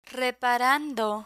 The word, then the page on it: reparando: spanish